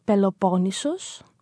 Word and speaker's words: Peloponnesos: Well, the Greek pronunciation of it is Peloponnesos, and Nisos means island, because it is like an island.